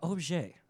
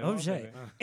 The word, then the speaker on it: Objet: The president of Objet. Did I say it right? – Yes. – Hey, Objet. It's spelled object, but we're fancy.